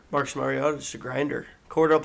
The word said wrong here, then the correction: Cordarrelle: Patterson. Did you guys see we're saying his name wrong? – What is it? – Cordarrelle Patterson. – That's what we have been saying. – Yeah, that's we've been saying. – Well, like Cordell. Name. I always say Cordell. – Yeah, you've been saying. – Yeah, you've been saying it wrong.